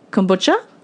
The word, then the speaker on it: kombucha: She sells, I always say this wrong, and Claire said it the right pronunciation, kombucha. And my children say you've had one since October last year and you still can't pronounce it properly.